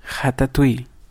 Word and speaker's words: Ratatouille: Ratatouille.